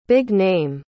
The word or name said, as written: big-name